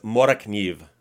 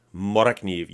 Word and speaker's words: Morakniv: And it's Morakniv. Morakniv. You pop the R and you hit the K. Morakniv.